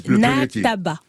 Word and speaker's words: Nataba: Candy, le premier titre de ton nouvel album, Nabata, c'est Wassendi. – Ouais, Nataba. Tonton. – J'ai prononcé ça de façon française, non? – Hein? Donc c'est Nataba qui veut dire ensemble.